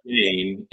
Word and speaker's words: Gayne: Gayne, and it's spelled G A Y N E.